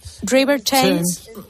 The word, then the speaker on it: Thames: River Thames.